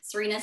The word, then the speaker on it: Serena: So at Serena Marie RD, Serena spelled like the tennis player.